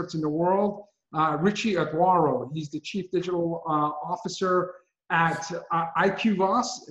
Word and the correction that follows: IQVIA: Did I pronounce that correctly, Richie? – IQVIA. – IQVIA.